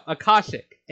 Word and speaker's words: Akashic: No, Akashic.